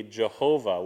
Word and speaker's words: Yahweh: But the best we can tell, it would be Yahweh, or you would have heard it maybe Jehovah was another way that people thought that it should be pronounced.